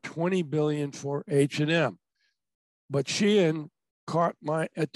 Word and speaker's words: Shein: So, as you know, Shelley, I wrote an article last week about this online, this online brand only called Shein, spelled S-H-E-I-N, and supposed to be pronounced as Shein.